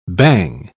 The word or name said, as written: bhang